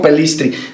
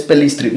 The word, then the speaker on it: Pellistri: Fue el único que dijo Pelistri. Todos los demás es Pelistri.